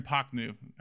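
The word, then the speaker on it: Prochnow: The lead actor in Das Boot, Jürgen Pachnu. I use Wikipedia pronunciation for that.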